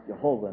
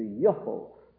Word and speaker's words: Jehovah: Now, that's not to say that the word wasn't pronounced, well, we can say quite definitely it wasn't pronounced Jehovah in ancient times, because the J is an English innovation anyway. Like Jesus, it was Jesus. There's no J in it, it's Yah. And the same is true in Jehovah. It would be Yehovah.